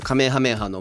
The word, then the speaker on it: カメハメハ: だから一応 YouTube で調べたんですよ、カメハメハの元の発音の仕方って YouTube で出てくるじゃないですか、うん、そしたらちゃんとカメハメハって出てきた、ね、あ、やっぱそうなんだなとかって思って、うんうんうん、それがなぜかで、もともとのアメリカの吹き替え版で、あの、ゴク、 can you do the come, やめやとかって言ってるんですよ。え、じゃあそもそも吹き替えが間違ってってこと？ – そうなんです。